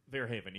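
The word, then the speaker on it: Verhoeven: No, I just, like, I think Paul, how do you say his last name? Verhaeven? – Verhaeven, yeah.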